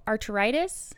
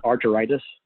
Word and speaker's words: arteritis: Giant cell arteritis? – Uh-huh. Arteritis.